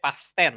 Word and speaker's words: Fast X: Ini bacanya Fast-nya atau Fast apa nih? – Fast-nya. Gue udah, gue udah, 10 ya, Fast Ten ini Fast Ten.